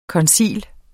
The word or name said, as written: koncilium